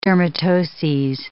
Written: dermatoses

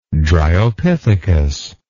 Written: Dryopithecus

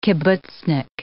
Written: kibbutznik

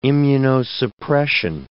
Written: immunosuppression

